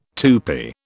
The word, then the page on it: Tupi: British English